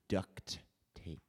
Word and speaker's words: duct tape: I think you're mispronouncing it. It's actually duct tape. – Yeah, was I saying duck? I say duck.